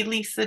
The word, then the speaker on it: Leesa: So my name is fully Leesa JoAnn Iverson, but I use that for my artist and illustrator voice.